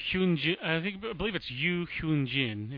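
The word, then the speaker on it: Ryu Hyun-jin: I believe it's Yu Hyun Jin, if you pronounce it Korean fashion.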